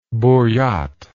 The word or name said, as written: buryat